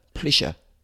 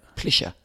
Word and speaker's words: Pleše: So 7:50, 11-minute victory, really good race by David Pleše. I'm pretty sure that's how you say it, from Slovakia. – Well, I read somewhere it's like saying Kesha, Pleše. – Yeah. – Pleše, yeah. Like Kesha the singer.